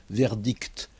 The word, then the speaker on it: verdict: Parce que ce mot « verdict » se termine par deux consonnes, « c » et « t ». Et ces deux consonnes, on les entend, « verdict », alors que, après le « c » et le « t », il n'y a pas un « e » final.